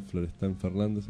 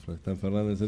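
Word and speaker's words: Florestan Fernandes: la Escuela Nacional Florestan Fernández. ¿Lo digo bien? – Muy bien. – Listo, Florestan Fernández.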